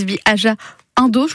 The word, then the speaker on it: Bi Aja Indo: Vous êtes travel planner chez donc pour votre entreprise Bi Aja Indo, je le dis bien? – Oui, alors Bi, c'est bien l'anglais, c'est BE. Aja, A-J-A et ça. Indo, I-N-D-O. – Voilà, comme le début d'Indonésie finalement.